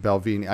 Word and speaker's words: Balvenie: Or Balvenie. Oh, God damn it. Balvenie. I even phonetically spelled it.